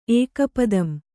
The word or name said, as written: ಏಕಪದಂ